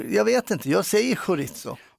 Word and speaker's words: chorizo: Så det är tjejljud fram och så är det hårt S på mitten. Chorizo.